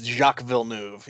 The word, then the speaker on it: Jacques Villeneuve: Tony Stewart, Kurt Busch, AJ Foyt, John Andretti, Danny Sullivan, Sam Hornish Jr. I'm not even going to try to pronounce that one. I think his name is Jacques Villeneuve. – It's Jacques Villeneuve, yeah.